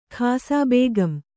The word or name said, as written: ಖಾಸಾಬೇಗಂ